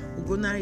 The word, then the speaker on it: Ugonna: Ugona is spelled U-G-O-N-N-A.